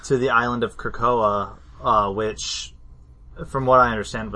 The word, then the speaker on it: Krakoa: And so they would send them to the island of Krakoa. Is that how you pronounce it? – You yeah, there's another nod, Krakoa. Yeah.